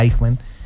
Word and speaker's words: Eichmann: H-A-N-N-A-H. Ya. Hanna Arendt. Perfecto. Y se habla, el libro es Eichmann, se pronuncia Eichmann, Eichmann en Jerusalén.